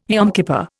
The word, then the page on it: Yom Kippur: Indian